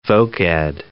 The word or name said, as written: voc-ed